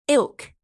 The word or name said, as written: ilk